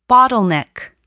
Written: bottleneck

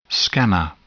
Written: scanner

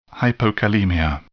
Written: hypokalemia

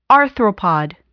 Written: arthropod

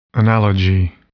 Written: analogy

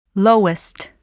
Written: lowest

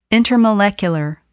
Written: intermolecular

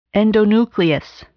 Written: endonuclease

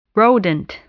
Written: rodent

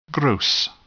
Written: gross

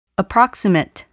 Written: approximate

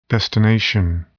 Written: destination

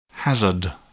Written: hazard

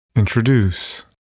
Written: introduce

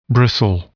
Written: bristle

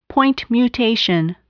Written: point mutation

